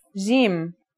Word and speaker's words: Jim: Our first letter is Jim. This one is easy to pronounce. It's somewhere between a G and a J, depending on the accent.